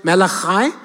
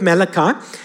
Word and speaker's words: Malachi: And I've been told by one or two people that it should be pronounced Malachi, but I'm Greek, so let's go with Malachi.